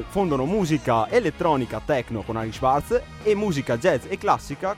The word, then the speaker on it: Henrik Schwarz: Sì, dalla pronuncia teutonica Henrik Schwarz.